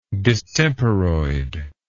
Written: distemperoid

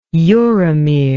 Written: uromere

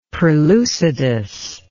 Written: perlucidus

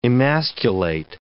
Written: emasculate

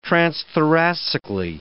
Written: transthoracically

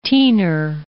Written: teener

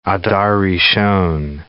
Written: Adar Rishon

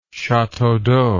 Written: chateau d'eau

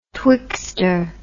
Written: twixter

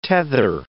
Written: tether

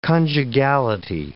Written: conjugality

